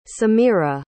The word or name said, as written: semira